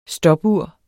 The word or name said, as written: stopur